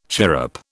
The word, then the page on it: chirrup: British English